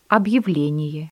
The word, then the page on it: объявление: Russian